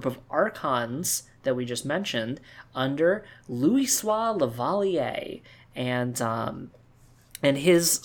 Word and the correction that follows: Leveilleur: That is not how you say his last name. – How do you say that last name? I never say it right. – It's Leveilleur. Leveilleur.